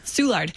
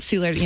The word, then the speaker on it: Soulard: Hopefully I'm saying that right. I got yelled at a long time ago. I said it wrong. Yeah. Soulard. – Not Soulard. – Yeah. Anyway, it's a St. Louis thing.